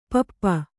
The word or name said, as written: ಪಪ್ಪ